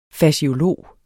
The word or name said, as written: faciolog